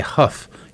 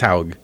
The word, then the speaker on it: Hough: There's a lady that works at the school district, and her name is Paula Haug, and every time I see it written, I want to say Huff. Is H O U G H, but that would be how I would pronounce it too. – Yeah, but it's Haug.